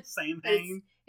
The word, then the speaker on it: Samhain: Also, it looks like it's spelled like Samhain. – Samhain? – It's Samhain. All right, that's the appropriate pronunciation.